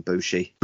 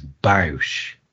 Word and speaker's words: Bush: Some people pronounce it as Bausch, and it's not. It's Bush. It's Bush.